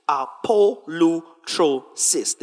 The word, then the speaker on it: apolutrosis: Apolutrosis, the way it is pronounced. It is spelled phonetically like that.